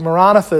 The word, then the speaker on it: Maranatha: In fact, I believe Maranatha is actually more probably the way it should be pronounced.